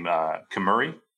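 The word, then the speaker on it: Kemuri: The company was given the name Kimuri, that's how you say it, to protect its identity.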